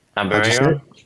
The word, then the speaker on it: Aberaeron: Aberaeron? – Aberaeron, okay.